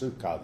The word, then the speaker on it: psyche: P-S-E-U-C-H-E. – P-S-Y-C-H-E. Pronounced sukha, though.